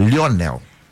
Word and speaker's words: Lionel: É, agora você falou o Lionel errado. Não é Lionel. – Lionel. – É Lionel. Lionel Messi.